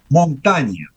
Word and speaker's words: Montaigne: Pero en el francés del siglo XVI, la I, que genera ese sonido en francés de E, una E abierta, cumplía otra función fonética y se pronunciaba montaña.